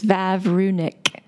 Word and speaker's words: Vavrunic: Yeah, it is of Czech origin, and it's one of those challenging last names, but it's really quite simple. It's Vavrunic. Three syllables.